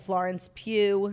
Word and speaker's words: Florence Pugh: That stars her boyfriend, Harry Styles, and Florence Pugh.